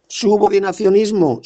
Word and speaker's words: subordinacionismo: Bueno, la cuestión es que el subordinacionalismo... – Subordinacionismo, no subordinacionalismo, como dices tú. A ver si aprendes a pronunciar bien esa palabra. Subordinacionismo.